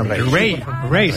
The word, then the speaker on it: Raye: Ray, se escribe Raye. Raye, con Raye, ya Raye.